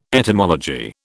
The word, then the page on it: etymology: British English